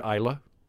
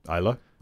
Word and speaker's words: Islay: Which is that an Islay? Where's that from? – That is an Islay. – Oh, really? Okay. – Am I saying that right? – I've always heard Islay. – Islay.